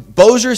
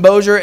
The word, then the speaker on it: Bossier: If you're not from Bossier, everybody calls it Bossier. They think it's French, and it's not. I mean, it is French, but that's not how you pronounce it.